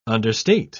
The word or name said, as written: understate